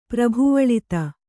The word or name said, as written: ಪ್ರಭುವಳಿತ